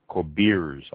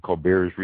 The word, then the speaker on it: Corbières: Going a little bit south of the Mirnavois region, you have the Corbières, I believe it's pronounced, south of the Mirnavois. It is primarily a Carignan growing region, Carignan grape. Carignan, C-A-R-I-G-N-A-N.